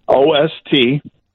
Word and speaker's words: Ostrowski: Ostrowski.